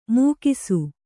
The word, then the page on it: ಮೊಗಸು: Kannada